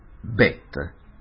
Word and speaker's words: Bet: Nella pronuncia quella Yud centrale salta e diventa Bet.